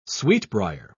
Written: sweetbriar

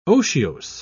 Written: otiose

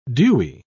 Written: dewy

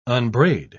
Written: unbraid

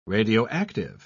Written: radioactive

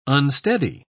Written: unsteady

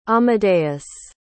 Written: amadeus

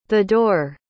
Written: thedore

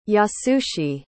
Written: Yasushi

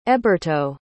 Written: eberto

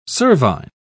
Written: cervine

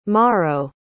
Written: maro

